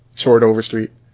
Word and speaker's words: Chord Overstreet: Cord is pronounced Chord. Chord Overstreet. – Chord Overstreet. Chord. – I don't know. – Actually, my name is pronounced like you're the Swedish chef. Like, it's Chord.